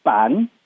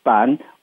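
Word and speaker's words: 粄: It's called ban. In Hakka, we pronounce it as ban, which is made from like a sticky rice.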